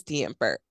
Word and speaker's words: DeYampert: It's at Mrs. DeYampert.